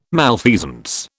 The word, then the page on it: malfeasance: British English